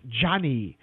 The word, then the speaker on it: Gianni: It's pronounced Johnny, like J-O-H-N-N-Y. – Right. – It's not Gianni.